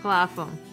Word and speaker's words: Clapham: On her way through Ch- Clapham, Clapham, I'm just trying to think. PH is a f sound. – Clapham or Clapham? Clapham. Okay.